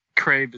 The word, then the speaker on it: Craib: And as I said, you know, we're joined by a guest today, Richard Crabe, or is it Cribe? Am I pronouncing that correctly, Richard? – Crabe is correct, yeah.